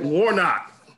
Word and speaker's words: Warnock: Warnoff? Did I say that right? – No. Nope. – You know you didn't. – Where's that sound? This magic moment. – Who was it? Warnock. – Warnock, yeah. – Yeah, Warnock.